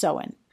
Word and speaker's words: Samhain: That is spelled Samhain, but it's pronounced Samhain.